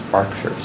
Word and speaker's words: Berkshire: there's a place in England called Berkshire, and it's spelled Berkshire, but it's pronounced Berkshire.